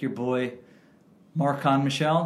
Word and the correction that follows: Marken Michel: did I pronounce that? – Well, Marcon, Markin. – Marken Michel uh, was open.